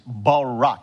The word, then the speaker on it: Barak: It's Barak, and in parentheses I put this is how you pronounce it, Barak, because I have a hard time pronouncing these uh, these Hebrew words.